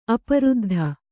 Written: ಅಪರುದ್ಧ

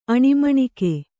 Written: ಅಣಿಮಣಿಕೆ

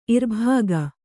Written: ಇರ್ಬಗೆ

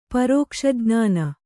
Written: ಪರೋಕ್ಷಜ್ಞಾನ

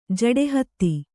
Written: ಜಡೆಹತ್ತಿ

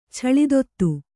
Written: ಛಳಿದೊತ್ತು

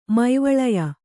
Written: ಮೈವಳಯ

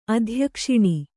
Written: ಅಧ್ಯಕ್ಷಿಣಿ

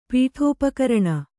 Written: ಪೀಠೋಪಕರಣ

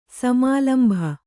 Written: ಸಮಾಲಂಭ

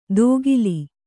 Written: ದೋಗಿಲಿ

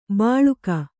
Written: ಬಾಳುಕ

